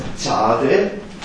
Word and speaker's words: Zade: Dieser Buchstabe heißt Zade. Und wird wirklich als Z ausgesprochen. Das ist unser Z. Zade. Es kann nicht hart und weit gesprochen werden,